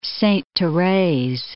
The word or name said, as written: Sainte-Therese